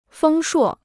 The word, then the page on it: 丰硕: fēng shuò